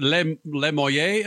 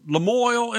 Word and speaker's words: Lamoille: How do you pronounce, is it Lemoyle, Illinois? How do you pronounce it? – Lamoille, Illinois. Oh, Jesus. Yeah. – I tried to go French, and it's as damn as American as you.